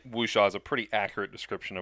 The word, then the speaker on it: Wuxia: But I've heard it called Wuxia. – Wuxia is a pretty I'm also not terribly proficient, but Wuxia is a pretty common pronunciation for the word.